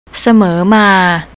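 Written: เสมอมา

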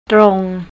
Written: trong

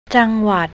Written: jangwat

